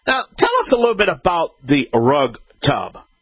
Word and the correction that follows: Rugged Tub: Well, we pronounce it Rugged Tub.